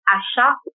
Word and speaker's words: Asa: lady in Africa called Asa, but it's pronounced Asha with an H.